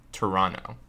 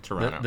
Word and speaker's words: Toronto: It's pronounced Toronto. – Toronto. – The second T is silent.